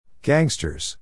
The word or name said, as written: gangsters